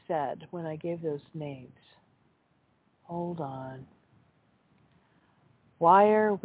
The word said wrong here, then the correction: Weyr: Weir is spelled W-E-Y-R, although I think it would be the same as W-E-I-R. So she says a pronunciation consensus for W-E-I-R by default, of course people are free to do it however they want with their own names, is Weir. So W-E-Y-R is probably also Weir.